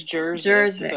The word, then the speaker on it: Jerzyk: Is it Jerzyk? Am I saying that correctly, Karen? – Yes, it is Jerzyk. So you should get some kind of award because most people don't pronounce it correctly.